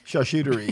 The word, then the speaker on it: charcuterie: Charcuterie. Is that how you say that? – Yeah.